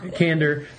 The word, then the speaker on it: Kandor: I mean, I don't think I heard you mention Kandor. I keep wanting to say it like Endor. Kandor.